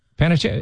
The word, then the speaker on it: Panettiere: I'm listening right now to the Hayden Panettiere audiobook. Did I say it right? – Panettiere.